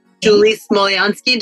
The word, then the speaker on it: Smolyansky: Julie Smolyansky.